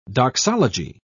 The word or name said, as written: doxology